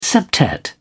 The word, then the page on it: septet: British English